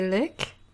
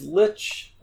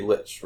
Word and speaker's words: Lich: You pronounce the wizard number 4, you say Lick? – It's Lich. It's not a word I use often enough to, like, have a natural pronunciation. It should be Lich, right? – I have no idea.